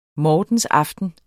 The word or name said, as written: mortensaften